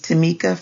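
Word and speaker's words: Tameka: Hello, my name is Tamika Fryer Brown.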